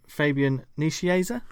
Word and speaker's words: Fabian Nicieza: So the writers were Scott Lobdell, Peter David, and Fabian Nicieza.